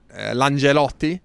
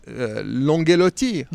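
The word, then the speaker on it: Langellotti: No, Pellacci, I wanted to speak to an Ineos Grenadiers rider, and it's a rider whose name I never know how to pronounce, because in Italian it should be Langelotti, but in Monegasque, because he is from Monaco, it's Langelotti, I suppose. French being the first language in Monaco.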